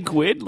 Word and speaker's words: GUID: Did you say GUID? Like squid? – Yeah. – That's awesome. Yes. – That's how I've always pronounced it.